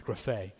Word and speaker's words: graphē: Okay, it's spelled like graph with an E at the end, graphē.